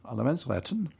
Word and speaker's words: Allemansrätten: Now, in your blog last December, you wrote that there's a law in Sweden, the country where you were born, called Allemansrätten. Allemansrätten. – Nicely pronounced.